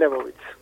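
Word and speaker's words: Lebowitz: sí, Fran Lebovitz.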